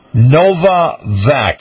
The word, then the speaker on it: Novavax: Okay, now, we have a company, Novavax.